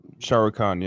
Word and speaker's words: Shah Rukh Khan: Shah Rukh Khan. Right? – That's how you say that? – Shah Rukh Khan, yeah.